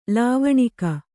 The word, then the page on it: ಲಾವಣಿಗೆ: lāvaṇige